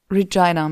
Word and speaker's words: Regina: Regina, mein Fehler. Jedenfalls Ryan Reynolds ist in Schwierigkeiten geraten in Regina. – Ja, weil sonst reimt es sich nicht. – Oh, wow, okay. – Rhymes with fun.